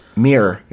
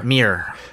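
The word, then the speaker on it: Muhr: The name of the guy who came up with the new variation on first they came for the, his last name is pronounced Mir. It's Lyle Mir. – Mir. – Okay. He says Mir rhymes with my favorite beverage, beer, or Mir like the Russian space lab.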